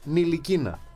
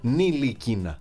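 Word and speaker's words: Ntilikina: Γιατί κάποιοι βγάζουν τα λεξικά και τη γραμματική τους, κανόνες, διφθόγκους, συντακτικά, δεν ξέρω τι άλλο έχουν κάνει, και λένε ότι το ντου, το νου του, δεν προφέρεται το ταφ. Άρα ο παίκτης λέγεται Νιλικίνα. Νιλικίνα.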